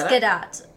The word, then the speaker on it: Gadot: Apparently, you pronounce her last name. – Like the T. – Oh. – The T. You Gadot. Pronounce it. It's Gadot.